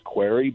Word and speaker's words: Query: And I do so from a personal standpoint as well, because my last name is Query, but it's spelled Q-U-E-R-Y, which is a very slippery slope.